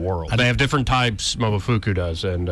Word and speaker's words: Momofuku: Oh, Momofuku. Or Fuku. – That was the best food. – Momofuku, yeah.